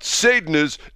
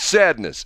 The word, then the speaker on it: Sadeness: Whenever I give the name, I'll always have somebody who says, it's not sadness, it's sadness. It's spelled on the album S-A-D-E-N-E-S-S, which would be sadness. – Sadness. – If you have an E after an A, it makes it long. So it's Sadness Part 1.